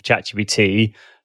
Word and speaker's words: ChatGPT: But yes, I want to run off now and go and have a look at ChatGTP and see. Did I just say it wrong again? I did. – Just to clarify as well, for those people interested, the GPT part of ChatGPT stands for generative.